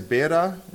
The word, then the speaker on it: Beira: This is the biggest city in that area, is Beira, is what it's pronounced in Portuguese.